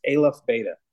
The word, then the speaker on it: Aleph: And I love, keep listening to the, how do you say the alpha thing? – It's Aleph. I've been saying it wrong. I say alpha. It's Aleph Beta.